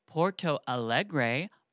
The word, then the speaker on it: Porto Alegre: And then we're going to Porto Alegre, Brazil.